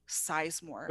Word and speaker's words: Sizemore: People, ne- that's like my name is literally Sizemore. – What do they say? Sizemore? – M O R E. The word size and the word more.